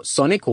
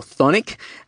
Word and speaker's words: Chthonic: Now, if you don't know who Freddy Lim is, Freddy Lim is the frontman for Chthonic or Chthonic, depending on who you are and how you pronounce it.